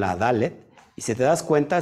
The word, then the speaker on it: Dalet: Eh, Dalet, lo que estás viendo aquí, pues es la, letra hebrea que tiene el valor en gematría de 4, pero eh, Dalet también se puede pronunciar como Delet porque Delet significa puerta.